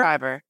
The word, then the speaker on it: Fiverr: And I am worried about, you know, people in other countries that really need, you know, companies like Fiverr. Is that how you pronounce Fiverr? Fiverr? – Fiverr, yeah.